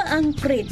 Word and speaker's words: อังกฤษ: วันหลังให้สังเกตให้ดีนะตัวรอรือต้องออกเสียงเหมือนรอเรือและออกเสียงให้ชัดด้วยคำว่าอังกฤษออกเสียงกอควบรอด้วยนะ